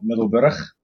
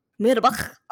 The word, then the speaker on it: Middelburg: Okay, first I'm going to pronounce it in a Dutch way. It's called Middelburg. – Middelburg. Okay, so it's Middelburg.